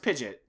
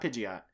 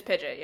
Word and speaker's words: Pidgeot: Which I feel doesn't count, and I'm pretty sure it's pronounced Pidgeot, not Pidgeot. – It's Pidgeot, yeah. – Yeah. – Like Pidgey, Pidgeot.